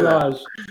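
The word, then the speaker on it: Lage: How do you say Lodge. – That? Lodge? Oh, that's tough.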